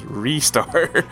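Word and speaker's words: Ristar: Also, the name Rystar is supposed to be pronounced as Restar. I believe it is a joke slash wordplay on the English word restart.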